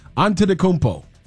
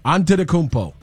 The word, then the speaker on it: Antetokounmpo: Giannis Antetokounmpo. – Giannis Antetokounmpo. – Antetokounmpo.